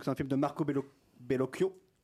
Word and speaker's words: Bellocchio: Marco Bellocchio. – Marco Bellocchio.